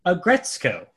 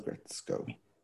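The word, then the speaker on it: Aggretsuko: Agretsuko. So when there's a S-U-K-O in a Japanese word, you do not pronounce the U. – It's, okay. – Sko. – Agretsuko.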